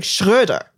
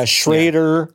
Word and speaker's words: Schröder: There's a dude on the Lakers who's from Germany, and his last name is an umlaut in it, and it's pronounced like Schröder. Right.